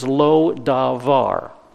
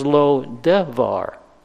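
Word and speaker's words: Lo-debar: Now Amos deliberately mispronounces the name of one of the recaptured towns. The recaptured town's name is Lodavar. Lodavar. Amos pronounces the name as Lodevar. Not Lodavar, Lodevar.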